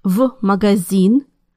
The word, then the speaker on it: В магазин: Now it's hard to say F магазин, so Russians pronounce it as the letter V instead. Listen again. – В магазин.